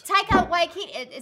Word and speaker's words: Taika Waititi: Taika Waititi, is that how you say his name? – Yeah.